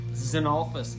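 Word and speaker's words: Xenophilius: Xenophilus Lovegood. – Xenophilus, yeah.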